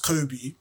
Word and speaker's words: Kaobi: Then the way it's pronounced, Kobe, anybody in the Western world or can hear it, and it's like, it's a name, do you know what I'm trying to say? It's Kobe.